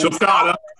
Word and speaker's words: sulcata: Sulcata.